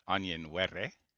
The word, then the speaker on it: Onyenwere: We were talking about the trade between Chicago and Phoenix, Kalia Copper and the rights to Mo Birch for Michaela Onyenwere. I'm saying it the way she told me to pronounce it.